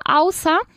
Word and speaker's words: außer: Haciendo énfasis en la primera sílaba, außer, y significa aparte de, ¿vale? – Muy bien.